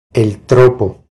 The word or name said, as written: El tropo